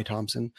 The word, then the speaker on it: Thomson: My first name is spelled E-R-A-N. Surname is T-H-O-M-S-O-N. No P. We call it a dry Thompson.